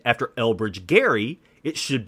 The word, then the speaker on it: Gerry: They requested that the Supreme Court use the proper pronunciation. You see, Marblehead, Massachusetts is where Elbridge Gerry was born.